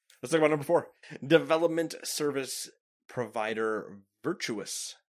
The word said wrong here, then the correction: Virtuos: No. That's not Virtuous. – I kind of pronounce it Virtuous. I guess you could say it's Virtuos. – Yeah. We're going to go with Virtuos.